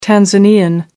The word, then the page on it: tanzanian: American English